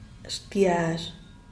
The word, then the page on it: shtiaxh: diaz_ordaz